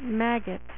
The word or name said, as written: Maggott